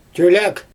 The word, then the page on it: tøjlæg: Xyzyl